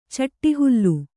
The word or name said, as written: ಚಿಟ್ಟಹುಲ್ಲು